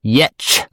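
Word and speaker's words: Giertz: So her product company is called Yetch Store, and that is the pronunciation for her name, which is spelled Gertz.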